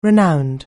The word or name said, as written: renowned